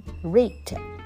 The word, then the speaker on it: wrinkled: S-L-Y A sly smile 狡猾的这个微笑呢挂在他这个充满皱纹的脸上On her face 叫做在她脸上那 wrinkled 这个 W 不发音我们从 R 发 R 的音 wrinkled 就是指充满皱纹的 What is wrinkle? Wrinkle 就是 W-R-I-N-K-L-E Wrinkle 是指皱纹 Smell lies in the skinWhen someone get older, 当人变得越来越老的时候呢，脸上就出现了一些 line, 一些线条。哈 wrinkled face.